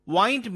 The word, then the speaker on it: wind: Now let's look into another familiar word, W-I-N-D. It's spelled same but pronounced as wind and wind. We all know wind as moving air. The wind is very strong today.